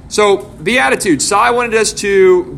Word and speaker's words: Beatitudes: Beatitudes.